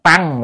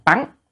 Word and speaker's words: tấn: Tăng, chữ này có hai âm, một âm là tăng, một âm là tấn. Ở trong cái câu mà oán tấn hội khổ đó quý vị, thì cái chữ này là đọc là tấn.